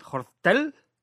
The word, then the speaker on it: Jortdel: Y sin embargo me crucé con el invitado de hoy, que en Twitter es, a ver si esta vez lo digo bien, Hortel, ¿no? – Muy bien.